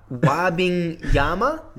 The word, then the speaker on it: Wembanyama: It's Victor Wabingyama.